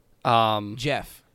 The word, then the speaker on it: Geoff: Jeff.